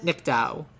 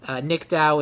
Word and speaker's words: Nicdao: Her name is Charlotte Nickdow. Nickdow is spelled Nicdao.